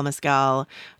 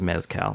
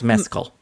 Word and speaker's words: Mescal: I'm realizing that because it's Irish, his name is probably not pronounced Mezcal, which is what I was saying. – Sorry. – I think you're saying it right. – Okay. I don't know that I'm saying it that differently, but yeah. Okay. – Maybe it's Mescal. – Mescal, Paul.